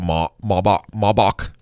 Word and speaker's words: Maybach: Actually, I think it's Maybach. B-A-C-H, because it's German.